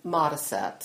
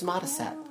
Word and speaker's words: Modisette: But it actually comes from Mothershed, which in England they pronounce without pronouncing the H's, so they say Mutterset, which is Modisette.